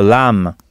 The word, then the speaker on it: lamb: Par exemple, un agneau qui court dans un pré, se dit « a lamb ». Ça s'écrit « L-A-M-B », mais le « B » final ne se prononce pas.